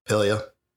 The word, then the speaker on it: Pelia: How do you say her name? Pelia? – Pelia. – Pelia.